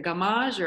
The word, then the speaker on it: Gammage: Is it Gammage or? – I've heard it pronounced every way. Gammage, Gammage, Gammage, but it's like damage with a G.